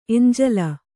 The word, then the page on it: ಎಂಜಲು: Kannada